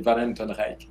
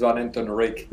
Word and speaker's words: Van Hentenryck: Well, I don't think there is an agreement of this, but typically the way I pronounce it, which is very difficult for Americans, is actually Van Hentenryck. Yeah, but you know, in the US, I basically, you know, I pronounce his Van Hentenryck because it's much easier for most people.